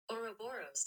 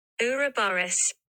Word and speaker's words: Ouroboros: Ouroboros. That's the American pronunciation.